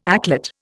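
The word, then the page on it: éclat: Indian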